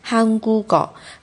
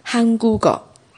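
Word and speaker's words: 韩 国 高 (한국어): 韩国高，韩国高，这就是一个连音化，就是把下面的收音连过去，连到后边。发音的时候是这样子的哦，这是音变。那写的时候呢，还是要写成韩国哦这样子的形态。读的时候读成韩国狗，韩国狗。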